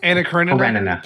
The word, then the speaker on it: Anna Karenina: I think she got a lot of notoriety in 2012 with Anna Karenina. Is that how you say it? – Anna Karenina.